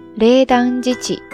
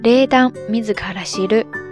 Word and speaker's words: 冷暖自知: 冷暖自知，它的音读可以直接读作“ 冷当自己”，冷当自己，冷当自己。如果是训读的话，可以读作“ 冷当米兹卡拉西鲁”，冷当米兹卡拉西鲁，冷当米兹卡拉西鲁。